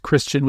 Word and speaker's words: Kristian: It's Kristian with a K, no H.